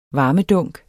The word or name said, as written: varmedunk